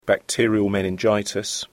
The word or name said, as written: bacterial meningitis